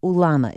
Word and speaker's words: ulama: This game of ulama, is that how you say it? – That's right.